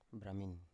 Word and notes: bramin: Biak

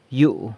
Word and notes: yu: house

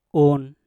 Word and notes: un: mpur

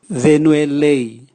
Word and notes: venue lei: Raga

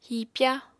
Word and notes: hipya: Apatani

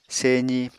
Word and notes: sezi: Apatani

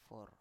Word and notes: for: Biak

